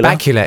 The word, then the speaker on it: Bakula: Bakula? – Bakula. It's like Dracula. – It is Dracula.